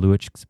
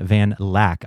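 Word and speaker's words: van Luijk: There is a quote from Ruben van Luijck's, actually it's pronounced Ruben van Lack. I just found that out, but it looks like Luijck.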